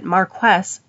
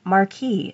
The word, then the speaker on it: Marquess: that Marquess is actually pronounced more like it's written and not Marquis, as I had incorrectly assumed.